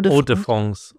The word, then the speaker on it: Hors de France: Normandie, Hors de France. Habe ich das gut ausgesprochen? Fast, oder? – Hors de France, okay.